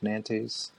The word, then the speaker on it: Nantes: Nantes. You don't pronounce the S. So it's like, Nantes.